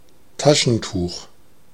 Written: tissue

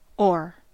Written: or

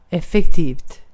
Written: effektivt

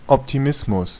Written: optimism

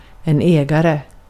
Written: ägare

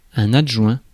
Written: adjoint

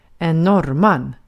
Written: norrman

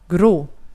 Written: grå

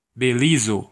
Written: Belize

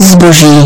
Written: vara

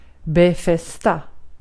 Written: befästa

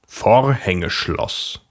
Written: замок

